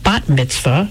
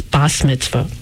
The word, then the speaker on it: Bat Mitzvah: It's more usually pronounced Bat Mitzvah, mm-hmm. but in the Ashkenazi accent, I heard it as Bas Mitzvah.